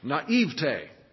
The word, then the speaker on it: naivete: We rarely use the word naivete, and there's different ways to pronounce it, but I guarantee you, according to the NBC Handbook of Pronunciation, it is to be pronounced naivete.